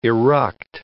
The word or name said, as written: eruct